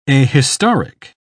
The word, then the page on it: ahistoric: British English